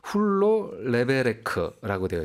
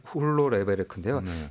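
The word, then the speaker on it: Hulló levelek: 그래서 이게 어떤 발음인가 한번 찾아보니까요. 훌로 레베레크라고 되어 있더라고요. – 훌로 레베레크. – 레벨릭. – 훌로 레베레크인데요.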